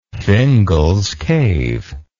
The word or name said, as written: Fingal's Cave